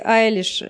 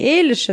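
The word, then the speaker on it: Eilish: Ну, то есть то, что Билли Айлиш, это Билли Айлиш, а не Эйлиш, это как бы сложно, ведь пока, пока я не услышала, э, как говорят ее имя.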